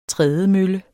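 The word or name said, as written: TRÆDEMØLLES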